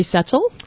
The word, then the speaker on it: Setchell: My name is Sherry Setchell.